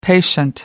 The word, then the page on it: patient: American English